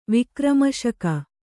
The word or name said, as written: ವಿಕ್ರಮಶಕ